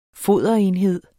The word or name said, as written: foderenhed